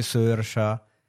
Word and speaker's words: Saoirse: Magari sai, visto che c'hanno le robe strane che si chiama, cioè Saoirse, che si legge Sersha, c'hanno queste cose particolari, no? – Barry.